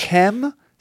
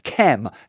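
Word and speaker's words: Khem: Kem spelled K silent H E M Birch. Kem.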